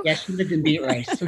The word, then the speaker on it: Beatrice: Okay, so any listener that is not from Nebraska or Iowa, the reason we're making fun of it is because Beatrice is spelled Beet Rice, B-E-A-T-R-I-C-E. Yeah. So that's why it looks like Beet Rice.